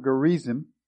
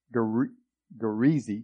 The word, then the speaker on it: Gerizim: You guys are going to be on Mount Gerizim, Gerizim, because the I is pronounced like an E, and the M is kind of not there.